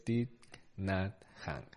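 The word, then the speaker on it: Thích Nhất Hạnh: así que no quería liarme mucho y por lo visto es Thich Nhat han.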